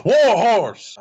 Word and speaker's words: Warhorse: Next up, we have the Independent Wrestling Championship, the champion Warhorse versus Tony Deppen. – You said that wrong. It's Warhorse. I'm gonna make your mic levels go all over because it's Warhorse. Say it like that.